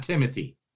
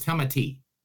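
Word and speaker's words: Timothée: It is not Timothy. – What is it? – It's Timothy. – Timothy. – That's what the long AE over it is. – Timothy. Okay. – No, it's not Timothy.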